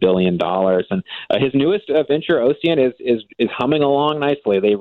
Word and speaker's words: Ocient: You wrote about a company I don't think I can pronounce, OCIENT, Ocient? – Yeah, Ocient.